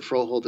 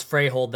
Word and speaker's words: Froholdt: You know, they might be looking at Froholdt as a guy to like slot in to replace Joe Thuney, you know, in next year, next offseason.